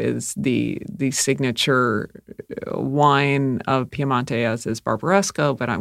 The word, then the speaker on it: Piemonte: And if you're, yes, that's the English pronunciation of Piemonte. The Italian is Piemonte.